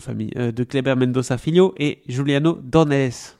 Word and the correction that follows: Dornelles: Dornelles. J'y suis arrivé.